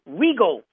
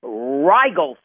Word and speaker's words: Rigel: Well, not only did I mispronounce the name, I mentioned to you as Regal Pharmaceuticals. Well, it's not Regal Pharmaceuticals. It's Rigel Pharmaceuticals.